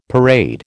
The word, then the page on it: parade: American English